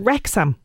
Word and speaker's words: Wrexham: uh, the streaming, uh, which is uh, Disney Plus this week, and it's Welcome to Wrexham.